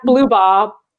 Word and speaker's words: Blubaugh: Is that how you say your last name? – Yeah, Blubaugh. – Blubaugh. – Some people say Bao.